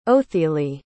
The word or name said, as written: othili